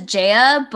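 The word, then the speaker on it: ZE:A: They pronounce it as Jaya, but mm-hmm.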